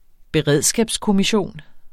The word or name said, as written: beredskabskommission